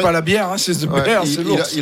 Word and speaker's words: The Bear: The Beer, l'ours, ouais. – Alors justement, il y a Aurélien qui nous dit que c'est peut-être. Euh, ça se prononce pas comme ça, en fait. The Bear. The Bear.